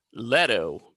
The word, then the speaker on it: Leto: I like to say Leto because it rhymes with Geppetto.